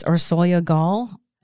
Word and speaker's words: Orsolya Gaal: I was afraid I would say it incorrectly also, honestly, but my understanding is Orsolya Gaal. I believe she's of Hungarian descent.